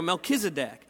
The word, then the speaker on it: Melchizedek: Milk in a deck. Okay, there we go, Melchizedek. And Melchizedek had a divine appointment. And again, I don't want to steal thunder from sermon a couple weeks, but Melchizedek shows up in Genesis chapter 14.